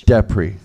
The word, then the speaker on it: Deprez: Yes, it's Flemish. – Depree.